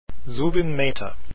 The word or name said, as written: mehta